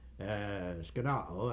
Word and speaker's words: skenoo: Now, the word skenao, which comes from the noun, that's the verb, skenao, I spelled S-K-E-N-O-O, the last O is the long O, it provides further meaning, further information as to the developed meaning of this word.